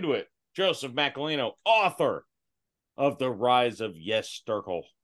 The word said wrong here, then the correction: Yezurkstal: Did I pronounce that correctly or no? You're laughing. – Yezerkstahl. That was yes.